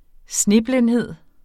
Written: sneblindhed

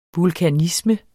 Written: vulkanisme

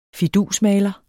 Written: fidusmaler

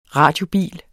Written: radiobil

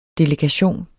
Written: delegation

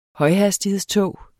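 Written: HØJHASTIGHEDSTOG

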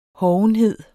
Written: hovenhed

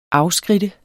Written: AFSKRIDTES

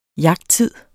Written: jagttid

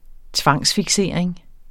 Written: tvangsfiksering

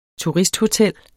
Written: turisthotel

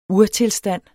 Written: urtilstand

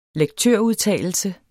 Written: lektørudtalelse

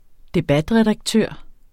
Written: debatredaktør

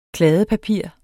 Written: kladdepapir

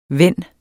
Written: vend

